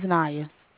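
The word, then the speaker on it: Niya: My name is Naya.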